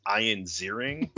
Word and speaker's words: Ian Ziering: So I went with, and I will pronounce this the name the way that he pronounces it, which is Ian Ziering.